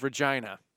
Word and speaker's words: Regina: The University of Regina. That's how you say it.